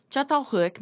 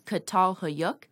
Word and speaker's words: Çatalhöyük: How I was originally taught to say the name was Çatalhöyük, as it's the equivalent pronunciation of the spelling in English.